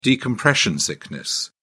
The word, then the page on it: decompression sickness: British English